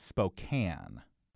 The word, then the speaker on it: Spokane: It's spelled Spokane. I believe it's Spokane.